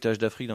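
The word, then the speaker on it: TH Da Freak: TH d'Afrique, c'est TH, plus loin DA, et Freak en anglais.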